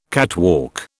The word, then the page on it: catwalk: British English